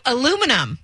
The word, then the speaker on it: aluminum: Lunamin. I can't do that word. It's the thing that soda cans are made out of. – Oh, aluminum.